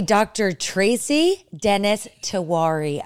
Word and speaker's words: Dennis-Tiwary: Okay, Dr. Tracy Dennis-Tiwary.